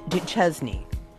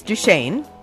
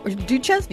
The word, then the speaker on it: Duchesne: Well, it's Saint Rose Philippine Duchesne. – Okay. – Or you thought it was Duchesne. – I did. – Yeah. Or Duchesne, either.